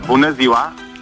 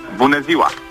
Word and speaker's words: Bună ziua: Bună ziua. – What does that mean? – Good morning. – Okay, how do I say it? – Bună ziua. – Bună ziua. – Okay, bună ziua. Exactly.